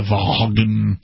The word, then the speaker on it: Vaughn: Now, is it Vaughn with like a silent G and an A? It's the G-H, yeah, V-A-U-G-H, ah, okay. And yeah, Vaughn.